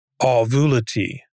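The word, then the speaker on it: Auvelity: Ovulity. I think it's pronounced that way because it should rhyme with velocity, because the one thing that this combo pill offers that's unique is a faster rate of onset.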